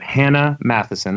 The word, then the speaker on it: Hannah Mathiesen: We have Hannah Mathiesen.